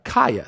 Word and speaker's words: Kaia: Cindy Crawford, her daughter's name is K-A-I-A. How do you say that? Kaya, thank you.